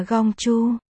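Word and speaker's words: gong chu (公主): Từ công chủ và công chúa trong tiếng Trung đồng âm, đều đọc là gong chu.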